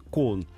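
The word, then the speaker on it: кон (कौन): Вот почему слово «кто» вы пишете как «каун», да, а говорите «кон».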